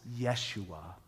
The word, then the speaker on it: Yeshua: His name was Jesus, which actually was probably pronounced Yeshua back then, which shows that God came into a culture.